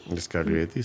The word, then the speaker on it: Scaglietti: Algunos autos con los que cuenta es Ferrari 335 S Spider Scaglietti. – Scaglietti, sí.